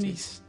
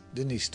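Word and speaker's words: Denys: Of Denijs, hoe spreek je dat uit? Denies. – Denies, toch hè?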